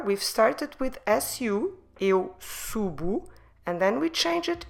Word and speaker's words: subo: So the first person is eu. Subo, eu subo. And here we have clearly the sound U, just like we have it on the infinitive. Subir, eu subo. Como se escreve subo? S-U-B-O.